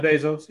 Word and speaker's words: Bezos: Bezos, ex-wife. I know I'm probably saying his last name wrong. – Yeah, Jeff Bezos. Yeah, I'm with you. – Yeah, Bezos.